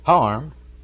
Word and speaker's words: Hiram: All natives around Hiram, Georgia, pronounce it Harm.